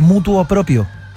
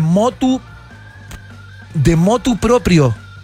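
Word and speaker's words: motu proprio: Dice, hola, yo antes decía de mutuo propio. – ¿Ya? – Pero en realidad es de motu propio.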